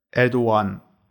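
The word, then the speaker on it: Erdoğan: Zum Beispiel Erdogan heißt nicht, schreibt man zwar Erdogan, aber dieses G ist ein weiches G. Das ist dann Erdogan.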